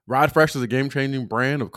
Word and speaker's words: Ride Frsh: So real quickly, in Blessed Report, we're going to shout out or bless up Ride Fresh. And that's spelled R-I-D-E-F-R-S-H. So got rid of the E.